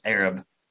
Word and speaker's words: Arab: grew up in a place called Arab, pronounced Arab, uh, or, Arab pronounced Arab, so you have, you have to bear with me here.